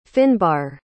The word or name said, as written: fynbar